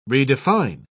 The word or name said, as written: redefine